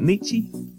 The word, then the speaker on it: Nietzsche: Evidently, I was smarter than I thought I was, because I just read that Nietzsche, is that how you say it, Nietzsche? – Nietzsche, yeah.